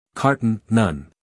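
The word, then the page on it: carton: uk ˈkɑːtn; en-us ˈkɑːrtn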